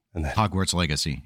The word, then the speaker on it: Hogwarts Legacy: Hogwarts Legacy is how it's pronounced in English.